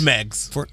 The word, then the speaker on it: Meigs: Is it Meigs? – It's Meigs.